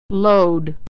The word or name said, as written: load